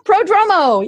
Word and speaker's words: Prodromou: Prodromou.